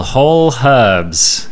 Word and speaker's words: Whole Herbs: It's called Whole Herbs. You do pronounce the H with this particular product.